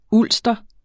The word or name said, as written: ulster